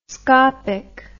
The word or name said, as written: scopic